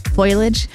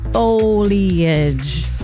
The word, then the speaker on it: foliage: I've heard some people mispronounce it lately as foliage. It's foliage.